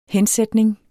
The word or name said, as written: hensætning